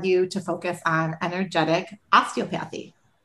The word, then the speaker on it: osteopathy: So just for people listening, we typically pronounce it osteopathy. It's one of those words that a lot of people have a hard time with, and it's totally fine.